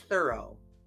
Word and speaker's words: thorough: And finally, there is thorough. It rhymes with burrow.